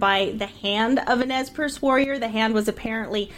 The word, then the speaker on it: Nez Perce: Have you ever heard people who aren't from the area, a lot of them will use the French pronunciation? – I hear Nez Perce a lot. I don't think that's the French pronunciation. – Yeah, no. In the French, a lot of people would say "Ne Perce."